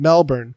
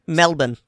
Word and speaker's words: Melbourne: Melbourne. I'm saying that wrong, people of Australia. My apologies. – Melbourne.